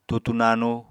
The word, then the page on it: tutu nanu: apatani